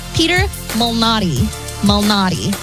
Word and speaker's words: Malnati: And I was saying it right. It's just now I'm not saying it right. Peter Malnati.